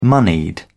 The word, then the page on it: moneyed: British English